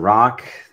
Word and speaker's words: Rak: My name is Dr. Rock. That is pronounced R-O-C-K, spelled R-A-K.